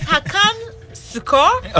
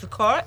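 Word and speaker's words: Hakan Şükür: So it's Hakan Shukor. – Okay. – Yes, Shukor.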